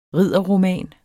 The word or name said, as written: ridderroman